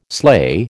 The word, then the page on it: sleigh: American English